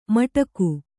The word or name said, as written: ಮಟಕು